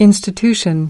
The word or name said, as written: institution